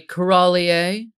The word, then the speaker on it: corallier: I am a corallier. You're familiar with a sommelier.